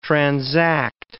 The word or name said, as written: transact